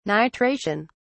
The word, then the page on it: nitration: British English